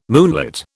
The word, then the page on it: moonlit: British English